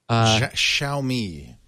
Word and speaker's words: Xiaomi: So they did single out three different manufacturers: Huawei, and I guess is it pronounced Xiaomi? – Xiaomi. – Xiaomi, which I've heard, of course, but yeah. I never saw in writing. Yeah. Xiaomi. – Xiao is little, I think.